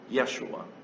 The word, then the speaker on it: Yeshua: his child Yeshua, as it was pronounced in Hebrew, Jesus, as it was pronounced in Greek, Jesus, as we pronounce it in our language.